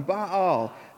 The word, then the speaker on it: Baal: And I'm going to keep saying Baal because there should be a little apostrophe in those two A's because that's what it sounds like when you read his name in Hebrew. So Baal, there you go. You guys say it with me. 3, 2, 1. Baal. All right. It's just how it's said.